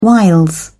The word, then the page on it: wiles: British English